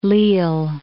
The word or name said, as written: Lille